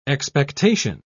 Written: expectation

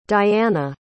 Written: dayana

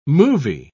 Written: movie

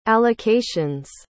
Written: allocation's

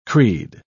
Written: creed

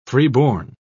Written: freeborn

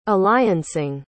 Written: alliancing

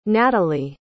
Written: natalee

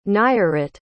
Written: nayarit

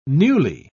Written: newly

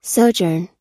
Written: sojourn

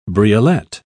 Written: briolette

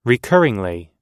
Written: recurringly

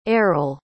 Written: Erol

